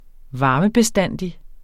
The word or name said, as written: VARMEBESTANDIGT